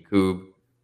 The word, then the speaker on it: Yakub: And then my Instagram is just Tajuddin Yaqub. So kind of how my name is spelled, T-A-A-J-U-D-E-N dot Yaqub, Y-A-K-U-B.